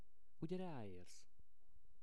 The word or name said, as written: ugye raersz